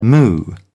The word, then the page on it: moo: British English